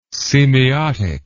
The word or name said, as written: semeiotic